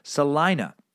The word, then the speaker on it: Salina: The town of Salina is pronounced with a long I sound, not a long E sound. Salina. And K-A-K-E News is simply pronounced as cake.